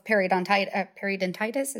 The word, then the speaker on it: periodontitis: actually have higher risk of periodontitis.